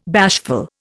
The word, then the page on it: bashful: Indian